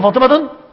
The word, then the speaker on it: فاطمة: عرفت ليه ما ينفعش أقول فاطمة؟ أقول فاطمة بضم واحدة، ليه؟ عشان ممنوع من الصرف.